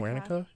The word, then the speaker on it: Guernica: And he has this memory of seeing, I think it's pronounced Guernica, right? Or like Guernica? – Guernica.